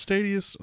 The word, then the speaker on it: Stadia: We're going to talk about Google Stadia, which I think that's how you pronounce Stadia. – Stadia. – I've heard it pronounced a couple different ways.